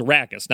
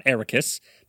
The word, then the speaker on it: Arrakis: First of all, they pronounced Arrakis wrong. It's Arrakis, not Arrakis, but I guess they had to fit the meter.